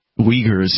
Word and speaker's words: Uyghurs: The Uyghurs? – Uyghurs, yes, correct.